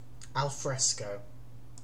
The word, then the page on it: alfresco: British English